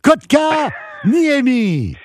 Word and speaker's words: Kotkaniemi: Il le prononce en deux étapes. Kotkaniemi!